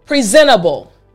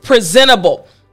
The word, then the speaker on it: presentable: Now, let me help you with the pronunciation a little bit more. So for this word, again, the word is presentable. What I want you to focus on is right here, presentable. All right? P-R-E-S-E-N-T-A-B-B-L-E.